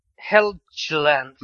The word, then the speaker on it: Helgeland: Och det är ett hårt kort G. Det är, alltså det är typ så här Brian Helgeland.